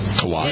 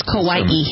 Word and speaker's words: Kauai: Kauai, is that how you pronounce it really? – Kauai. – It's Kauai. – We went through this last week. – It's Kauai. – Kauai? – Kauai. – Like Hawaii with a K. – K-A-U-A-I is Kauai?